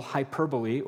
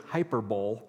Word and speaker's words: hyperbole: Satire is using humor, irony, exaggeration, ridicule, hyperbole, or as Brian Regan would pronounce it, hyperbole, um, to point out the stupidity, the foolishness, the, the just complete inanity of people, their behavior, society, whatever that target is.